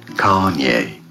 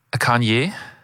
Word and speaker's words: Kanye: Kanye. – Kanye, finde ich, sagst du super. – Ich glaube, die Betonung ist auf der ersten Silbe.